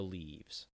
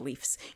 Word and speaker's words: Leafs: The Leaves. – Leafs. – Leaves. – It's pronounced Leafs.